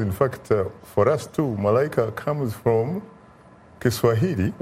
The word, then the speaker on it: Malaika: So I actually wanted to So my Malaika is spelled M-A-L-I-K-A, right? But the way it's supposed to be pronounced is M-A-L-A-I-K-A.